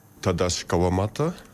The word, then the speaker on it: Tadashi Kawamata: D'ailleurs, son nom se prononce plutôt comme ça. – Tadashi Kawamata, artiste japonais.